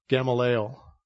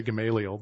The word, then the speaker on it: Gamaliel: You remember back in chapter 5, I want to say it like David said it, Gamaliel. So, because I always say Gamaliel, but I want to be united front here.